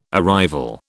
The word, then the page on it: arrival: British English